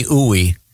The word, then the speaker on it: Ooi: Her, I swear to God, her last, it says, I'm not kidding, Dr. Stephanie Ooey. It's spelled O-O-I. – C-Cruise, right? – Yeah. Ooey, baby. Yeah. Ooey. Dr. Stephanie Ooey, there's a pronouncer here, and it says it rhymes with gooey.